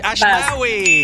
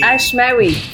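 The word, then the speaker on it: Ashmawy: Ashmawi. – Ashmawi.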